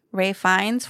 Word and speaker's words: Ralph Fiennes: Ralph Fiennes for Conclave, I said it right, Sebastian Stan for Apprentice.